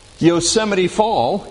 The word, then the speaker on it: Yosemite Fall: switchbacking trail that goes to the top of Yosemite Fall in Yosemite National Park. You notice I said Yosemite Fall because that's the correct pronunciation. They're not falls. It's just one fall.